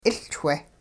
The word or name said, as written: 'iLchwe